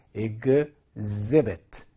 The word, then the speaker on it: exhibit: 발음 또한 여러분들이 자 한번 생각해 보시고 정확하게 좀 구사를 잘 하셨으면 좋겠어요. exhibit, exhibit, exhibit 이렇게 발음합니다.